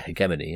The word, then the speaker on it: hegemony: Do you guys actually say it with a hard G? – Yeah, hegemony. Yeah.